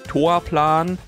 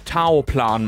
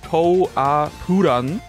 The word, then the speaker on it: Toaplan: des Studios falsch aussprechen, weil mein Kopf immer automatisch aus Toaplan Taoplan macht. Einfach weil es phonetisch so viel besser klingt. Die Firma eigentlich wirklich traditionell im Japanischen heißt Toapuran, ne?